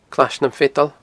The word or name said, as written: Clais nam Feadan